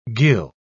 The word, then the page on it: ghyll: British English